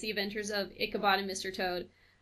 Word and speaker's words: Ichabod: The Adventures of Ichabod and Mr. Toad.